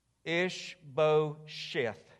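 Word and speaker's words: Ishbosheth: Ish-bo-sheth.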